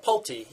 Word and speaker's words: Pulte: What about Pulte Group? Is that how you pronounce it? – Pulte, yeah.